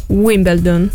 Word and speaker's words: Wimbledon: Mentre Lorenzo ti dà ragione, Niccolò, sulla pronuncia è Wimbledon.